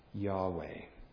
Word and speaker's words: Yahweh: And as best as we can tell, the name is properly pronounced Yahweh.